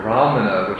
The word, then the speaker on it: brahmana: Now I think, this is according to some Pali scholars, it's very likely that in the original language that the Buddha spoke, the word brahmana, which is a Sanskrit word, was actually pronounced something like bhamana, without the ra.